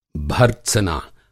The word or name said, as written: भर्त्सना